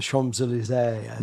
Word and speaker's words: Champs-Élysées: Paris, the Louvre, Versailles, going down the Champs-Élysées.